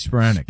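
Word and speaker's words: Spiranac: We had Paige, I always want to say, is it Spironik or Spironik? – Paige Spironik. – Spironik. – Yeah, you always get in my head, make me think I'm saying it wrong. – How do I get in your head? I'm the one that said it right.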